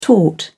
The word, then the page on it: taut: British English